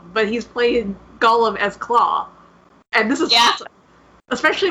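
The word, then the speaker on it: Klaue: Can we just say, I think I'm going to say his name right, Claw? – Yeah. Yep. – Claw.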